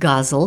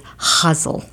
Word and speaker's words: ghazal: A lot of people tend to pronounce it guzzle because it's spelled G-H-A-Z-A-L. But I remember hearing the poet Shahid Ali read at Breadloaf one year, and this was his form. And he talked about it, he lectured about it, and he said it's huzzle.